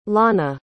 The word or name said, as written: lana